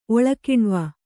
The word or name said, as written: ಒಳಕಿಣ್ವ